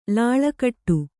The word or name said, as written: ಲಾಳಕಟ್ಟು